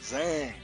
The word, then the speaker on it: Zhang: Joining me on the program, Zeta Zhang. I might be mispronouncing the last name. You know, I'm hard with you. No, you got it right.